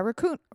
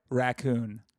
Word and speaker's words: raccoon: I lean hard on the first syllable, but that might be a Southern thing. Because I'm a hard, like, raccoon.